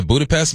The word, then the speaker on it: Budapest: Next up, soccer hate is deep in this Chelsea supporter traveled from Kenya all the way to Budapest.